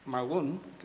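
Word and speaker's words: magün: magún, que se pronuncia, se dice en mapuche, y pudimos filmar ese día.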